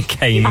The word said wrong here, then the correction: Kanye: Not only did she mispronounce Kanye, it was also a song that's been guessed twice before.